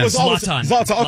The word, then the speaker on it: Zlatan: Yeah, I used to call him Zoltan. – Zlatan. Okay. Zlatan. Even the beef between, you can tell those two guys do not like each other.